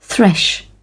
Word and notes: thresh: British English

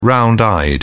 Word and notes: round eyed: British English

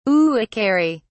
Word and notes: uakari: British English

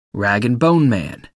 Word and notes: rag-and-bone-man: American English

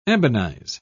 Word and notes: ebonize: British English

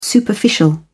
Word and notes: superficial: British English

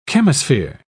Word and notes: chemosphere: British English

